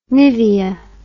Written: nivea

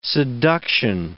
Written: seduction

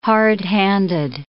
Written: hardhanded

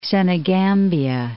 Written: Senegambia

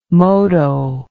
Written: moto

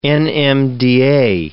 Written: NMDA

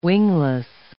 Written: wingless

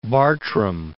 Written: Bartram

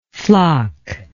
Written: flockless

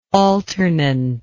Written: alternant

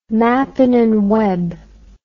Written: Mappin and Webb

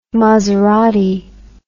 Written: Maserati

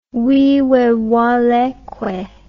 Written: vive valeque

